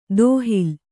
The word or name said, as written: ದೋಹಿಲ್